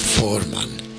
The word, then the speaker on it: Voormann: Me refiero a un señor que le llaman Klaus Voormann, porque se escribe con V, con B corta, pero en realidad habría que, como es un apellido alemán, esa B corta, esa V, tendría que pronunciarse como una F, ¿no?